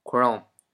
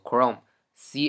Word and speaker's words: Chrome: 我说我用的是 Chrome，很多朋友说这是什么东西？直到我说我用的是谷歌浏览器，大家才反应过来，说哦，原来谷歌浏览器的英语叫做 Chrome。 Chrome，大家要知道怎么读。谷歌浏览器英语叫做 Chrome，C H R O M E，Chrome，C H R O M E，Chrome 叫谷歌浏览器。